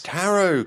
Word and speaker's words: tarot: Tarot cards. – Yeah, it's spelt tarot. – Tarot. Like carrots, but with a T.